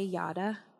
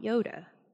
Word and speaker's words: yada: I'll say yada, not yoda.